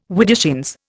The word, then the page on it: widdershins: Indian